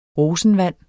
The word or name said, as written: rosenvand